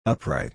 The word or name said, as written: upright